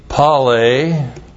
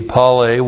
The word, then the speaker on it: pale: You had the dramas, D-R-O-M-O-S. These were the running events. You had the pale, P-A-L, long E, pale, were the wrestling events. You had the pugme, P-U-G-M, long E, whoops.